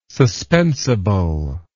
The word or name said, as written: suspensible